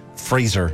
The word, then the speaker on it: Fraser: Brendan Fraser. – Yeah, Brendan Fraser. – Yep, he won the Oscar. – Is it Fraser or Fraser? – Fraser. – We all said like laser. – Yes, it's like laser.